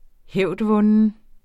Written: hævdvunden

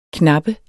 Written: knappe

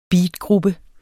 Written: beatgruppe

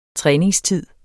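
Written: træningstid

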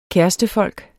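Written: KÆRESTEFOLK

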